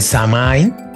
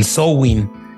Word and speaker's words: Samhain: Pero bueno, digamos que el Halloween inicia con, tiene como su antecedente eh, primigenio esta, esta fiesta que se llamaba el Samain o por bien pronunciado es el Sowin,